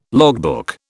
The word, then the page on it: logbook: British English